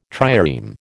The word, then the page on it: trireme: American English